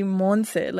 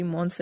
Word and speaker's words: Monse: It has to be Monse. Like, it had, like, at least in the pronunciation of my name, it has to be Monse.